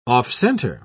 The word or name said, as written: off-center